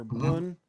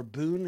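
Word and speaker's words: bún: You use it for Vietnamese egg rolls and for bun or bun is how it's pronounced, which is a grilled meat, noodle bowl.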